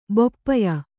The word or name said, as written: ಬೊಪ್ಪಯ